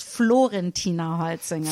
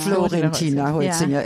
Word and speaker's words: Florentina Holzinger: Ist, ich muss dich korrigieren, es ist Florentina Holzinger. – Florentina Holzinger, ja, Entschuldigung.